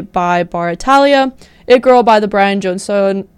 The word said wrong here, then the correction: Jonestown: John Stone Massacre. – Jonestown.